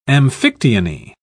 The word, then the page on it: amphictyony: British English